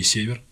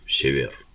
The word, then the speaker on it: Север: Потом знаменитый римский император Септимий Север, один из самых сильных римских императоров. – Извините, поправлю. Север. Север.